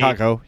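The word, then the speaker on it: Kakko: Uh, it's it's Capo, right? – Like Capo I, Caco. Yep.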